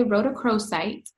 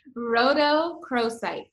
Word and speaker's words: rhodochrosite: I'm going to say rhodochrosite. – Okay. How do you say that again? – Rhodochrosite.